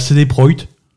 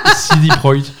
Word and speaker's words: CD Projekt: Alors, visiblement, officiellement, ça se prononce CD Projekt. CD Projekt.